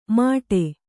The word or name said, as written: ಮಡೆ